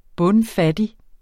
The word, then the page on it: bundfattig: ˈbɔnˈ-; ˈbɔnˌ-